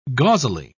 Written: gauzily

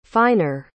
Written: finer

